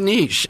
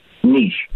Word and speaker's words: niche: They say niche. Is that what you say? – I do. Niche.